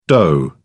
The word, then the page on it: doh: British English